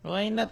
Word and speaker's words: Why Not: Dapat ikumplito niya nga Why Not. Yun katong W-H-Y. Why Not naman po.